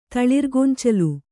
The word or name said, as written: ತಳಿರ್ಗೊಂಚಲು